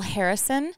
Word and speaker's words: Harrison: Harrison, and that's Instagram, Facebook, and Twitter.